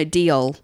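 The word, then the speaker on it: Deol: So, Harleen Deal. – Deal. Deal? – No, Diol.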